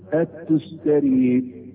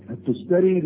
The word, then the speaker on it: التستري: فقاعدة اللي يسموه تفسير القرآن العظيم للتستري، هكذا تنطق الكلمة التستري. التستري نسبة الى مدينة اسمها تستر، ودي من بلاد فارس وعفين، من حتة اسمها خوزستان، من بلاد فارس يعني.